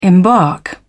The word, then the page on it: embark: British English